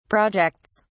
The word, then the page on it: projects: British English